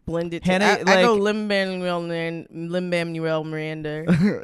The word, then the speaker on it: Lin-Manuel Miranda: How do you say his name? – Lin-Manuel Miranda. But I call him Hamilton.